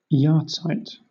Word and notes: yahrzeit: British English

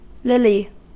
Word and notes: lily: American English

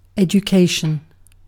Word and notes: education: British English

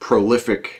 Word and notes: prolific: American English

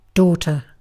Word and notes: daughter: British English

